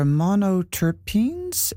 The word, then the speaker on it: monoterpenes: Yeah, there's one of the most abundant compounds you write about given off by trees are monoterpenes.